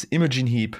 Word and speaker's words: Imogen Heap: Ähm, dann ich von Imogenhieb. ähm, ja. Imogen, glaube ich, heißt es. Imogen Heap.